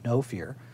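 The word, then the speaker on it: Know Fear: We'll have an interview with the director of No Fear, and it is spelled K-N-O-W, No Fear.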